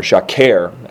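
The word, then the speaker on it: shaker (שֶׁקֶר): That word lying is the Hebrew word sheker, S-H-E-Q-E-R. But again, you can spell it with whatever English letters make you say shacher, or shaker, actually.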